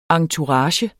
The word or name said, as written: entourage